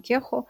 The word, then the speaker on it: Кехо: Я очень люблю Джон Кихо. Джон Кехо или Кехо, правильное ударение.